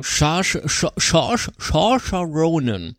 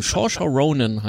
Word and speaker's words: Saoirse Ronan: Saoirse Ronan. Ja? Einfach ins Mikro rein nuscheln, wird schon stimmen. Saoirse Ronan heißt sie.